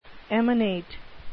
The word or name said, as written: emanate